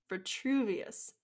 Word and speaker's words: Vitruvius: But at this time, we see the wizard Virtuous. Did I say his name right? – Vitruvius.